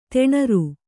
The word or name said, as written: ದಿನಾರು